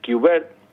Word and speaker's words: Kubert: Kubert, pronunciado así en inglés fino, digamos, ¿no? Pero Kubert con K y con B para nosotros, ¿no? Para entenderlo bien.